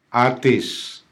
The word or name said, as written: atis